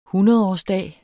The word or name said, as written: hundredårsdag